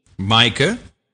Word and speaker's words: Maaike: But let's start with one from the Netherlands, because you're going to have to help me pronounce a $75 donation from, it's spelled M-A-A-I-K-E. – Micah. – Is it Micah? Is that Micah.